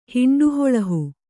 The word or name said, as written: ಹಿಂಡುಹೊಳಹು